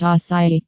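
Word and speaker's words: açaí: Aki, pronounced acai, berries stem from a palm tree in the rainforest of Brazil.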